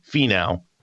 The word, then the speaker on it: Finau: Over the last 50 rounds, Tony Finau, I'm not saying this wrong, this is correct.